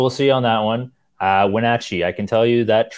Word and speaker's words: Wenatchee: Thank you, by the way, for correcting me on the pronunciation of that. – Wenatchee, yes. Wenatchee.